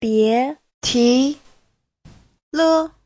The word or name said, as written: bie2ti2le5